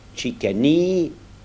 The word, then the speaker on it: Chicani: En appelant le roussi Chilaoui, avec l'accent tonique sur la première syllabe, et l'autre Chicani, avec l'accent sur la dernière syllabe, on apprit à chacun à connaître son nom et tout finit par s'arranger.